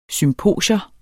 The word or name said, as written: SYMPOSION